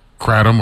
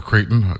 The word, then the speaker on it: Kratom: I call it Kratom, but I've heard it pronounced the other way.